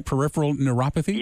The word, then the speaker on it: peripheral neuropathy: Peripheral neuropathy? – You got it.